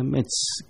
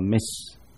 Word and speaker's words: Metz: 他占领了 Metz 这个城市以后，这个 Metz 看起来像德国字，可是他好像在法国境内，如果法语来读叫做读作 Metz 啊，用英文来读，用德文来读读作 Metz M-E-T-Z 这个字。